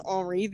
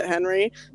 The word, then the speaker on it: Henri: But I found the book called Henry, and it's spelled like Henri, so I was like pronouncing it in my head Henri the entire time. – Mm-hmm. – But he's actually just from New Orleans, and everyone pronounces it Henry, but he's Cajun, okay. So it's spelled like Henri.